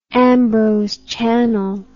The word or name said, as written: Ambrose Channel